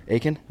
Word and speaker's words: Aitken: Aitken? – Yes, sir.